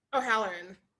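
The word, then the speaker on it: Hallorann: O'Halloran.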